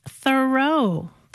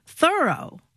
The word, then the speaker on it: Thoreau: All this time, I thought his name was pronounced Thoreau, but no. – How is it pronounced then? – Thoreau. – I thoroughly did it. – Yeah, or I'm thoroughly surprised that it's pronounced that way.